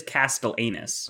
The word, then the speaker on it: Castellanus: But it is spelled Castellanus. So it is Castellanus.